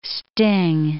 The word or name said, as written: sting